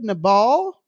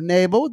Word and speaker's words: Nabal: We're talking about David, Nabal, or Nabal. It's pronounced two different ways.